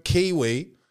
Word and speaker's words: Qiui: It's called the Cellmate Chastity Cage, built by Chinese firm Kiwi, and that's spelled Q-I-U-I, just so you know, all right?